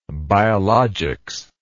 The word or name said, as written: biologics